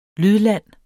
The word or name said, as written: lydlande